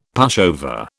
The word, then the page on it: pushover: British English